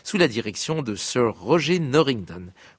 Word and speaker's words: Roger: Oui, je dis bien Roger et non Roger, car Norrington, un jeune chef d'orchestre dynamique et joyeux de 82 ans, tient à ce que l'on prononce son prénom à la française.